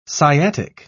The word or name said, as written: sciatic